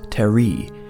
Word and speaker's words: Terry: At the party, Imogen meets Terry. Emphasis on his final syllable, please.